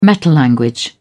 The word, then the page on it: metalanguage: British English